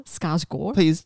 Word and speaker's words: Skarsgård: Bill Skarsgård. – Please don't come for us, Ollie. – Apparently you don't say the D on the end.